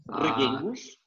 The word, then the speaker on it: Reguengos: I'll spell it for you. R E G U E N G O S, Reguengos. Ah, Reguengos.